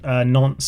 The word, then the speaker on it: Nantes: I had an amazing Nantes save.